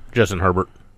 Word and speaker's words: Herbert: Justin Herbert. – Okay. – Justin Herbert. – So he does pronounce the T? – Yeah.